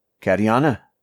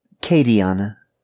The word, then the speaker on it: Katiana: "How's it going, Katiana?" he asked, pronouncing it like cat. – "Katiana," she corrected, like Kate.